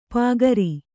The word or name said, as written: ಪಾಗರ